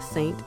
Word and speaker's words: SXXNT: And right before that, we had Saint, which is spelled S-X-X-N-T, but pronounced Saint, featuring Trump and Pete with Middle of the Mystery.